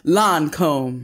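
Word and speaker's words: Lancôme: That's how you say it. – Lancome. – That's it.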